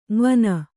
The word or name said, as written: ಙವನ